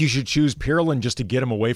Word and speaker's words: Pirulin: P-I-R-U-L-I-N, Pirulin.